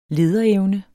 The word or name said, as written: lederevne